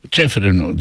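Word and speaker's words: Čeferin: Ceferin nasıl okunuyor? Seferin mi? Ceferin mi? – Ceferin diye okuyorum. Hani yani Ceferin, Ceferin öyle oku, geldi.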